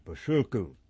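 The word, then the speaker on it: Bociurkiw: Again, B, O, C, I, U, R, K, I, W. I'm going to pronounce it Boshurku.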